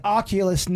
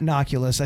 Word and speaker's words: Oculus: And it's Oculus, not Noculus.